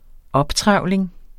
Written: optrevling

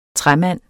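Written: træmand

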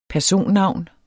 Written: personnavn

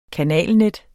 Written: kanalnet

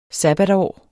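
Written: SABBATSÅR

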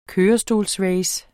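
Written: kørestolsrace

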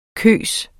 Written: kyst

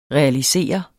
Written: realisere

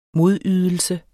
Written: modydelse

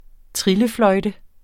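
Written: trillefløjte

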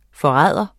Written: forræder

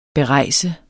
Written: berejst

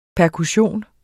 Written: perkussion

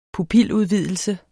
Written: pupiludvidelse

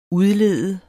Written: udlede

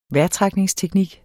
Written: vejrtrækningsteknik

